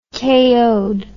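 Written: KO'd

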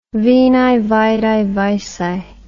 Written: veni vidi vici

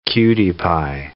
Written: cutie-pie

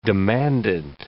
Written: demandant

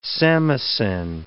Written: samisen

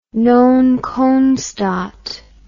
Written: non constat

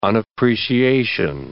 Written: unappreciation

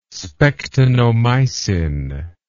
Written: spectinomycin

